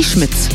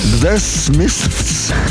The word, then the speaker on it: The Smiths: Ja, und du hast es jetzt deutsch ausgesprochen. Für uns deutsche Moderatoren ist das jedes Mal eine Herausforderung, es auszusprechen. – Ich sag's dir, Zungenbrecher. – The Smiths. So heißt es eigentlich richtig.